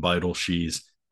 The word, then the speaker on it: Beidelschies: They have guys like Gabe Gackel, Landon Beidelschies, which that's the correct pronunciation. I made sure before I interviewed him to talk with him and get that pronunciation down because I know Beidelschies is a name I'm going to be saying a lot this season.